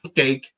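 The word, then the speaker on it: Tony Kaye: Sí, lo dije bien. Tom Cake.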